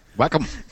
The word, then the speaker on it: Wacom: How do you pronounce that's the other question. Is it Wacom or Wacom? – Wacom. – Okay, let's say it's Wacom.